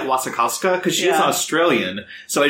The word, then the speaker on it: Wasikowska: Is that how you pronounce her name? – Wasikowska, yeah.